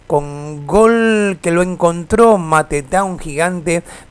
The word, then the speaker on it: Mateta: Mateta,